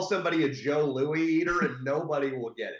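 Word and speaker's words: Jos Louis: Joe Louis, but it's spelled J-O-S and then Louis with an S. Like Joss Louis would be a joke we would say when we were kids.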